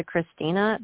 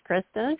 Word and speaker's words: Krysten: I must look more like a Christina because I will always get Christina. And I'm like thinking, I introduced myself as Kristen, but I always get Christina.